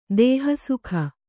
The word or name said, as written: ದೇಹಸುಖ